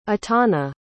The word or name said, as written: etana